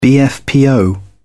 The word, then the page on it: bfpo: British English